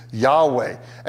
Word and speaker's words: Yahweh: That's the name Yahweh. That's best guess how we pronounce that. Yahweh.